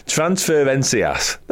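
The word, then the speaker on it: Transferências: This comes from Jorge Das Transfercens. I can't pronounce that word. Transferencias. There we go.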